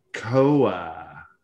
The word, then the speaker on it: Kowar: Jackson Kowa. I think that's how you pronounce it. K-O-W-A-R. Kowar.